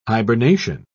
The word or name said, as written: hibernation